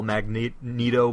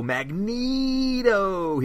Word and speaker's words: Magneto: And the Toad call Magneto Magneto here, or the Angel does. – Oh. – Helps you with the pronunciation in case you get it wrong. – Yeah, but he's not Neato. He's like a magnet.